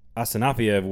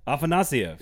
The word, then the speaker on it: Afanasyev: See, you didn't say it right there. – What did I say? – You said Afanasyev, I think. – Afanasyev. I've said his name correctly for years. Why can I not get it right now? – Get it right. – Afanasyev. Afanasyev. – There you go.